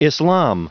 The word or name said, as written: islam